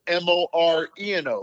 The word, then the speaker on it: Moreno: It's Moreno, like Rita or Omar.